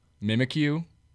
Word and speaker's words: Mimikyu: Mimikyu.